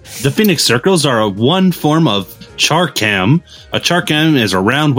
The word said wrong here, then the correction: chakram: weapon, kind of like chakram. – A... Yeah, it's pronounced chakram.